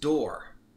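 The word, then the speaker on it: dor: It's pronounced door.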